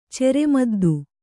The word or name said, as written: ಚೆರೆಮದ್ದು